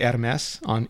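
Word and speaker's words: Hermès: It's technically Hermes in French, because you got to roll the R and then mess.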